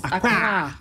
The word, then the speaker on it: Accra: But I grew up all of my life calling it Accra. I was told here it's called Accra.